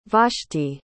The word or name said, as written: vashti